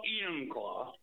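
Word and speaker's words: Enumclaw: Emmonclaw, Washington. Am I saying that right? – Well, Emmonclaw.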